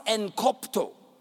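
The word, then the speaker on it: enkoptō: That word hindered in Greek it's spelled E G K O P T O. E G K O P T O. But it's pronounced encopto.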